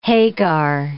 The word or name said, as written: Hagar